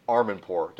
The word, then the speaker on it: Armandpour: We've got Tim Armandpour.